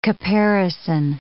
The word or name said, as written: caparison